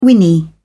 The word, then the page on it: whinny: British English